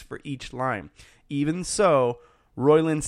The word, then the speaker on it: Roiland: Co-creator Justin Roiland. Am I saying that right? – Roiland.